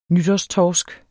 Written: nytårstorsk

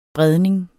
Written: bredning

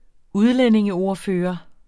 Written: udlændingeordfører

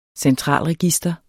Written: centralregister

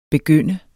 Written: begynde

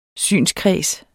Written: SYNSKREDSEN

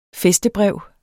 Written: fæstebrev

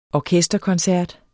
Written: orkesterkoncert

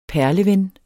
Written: PERLEVENNERNE

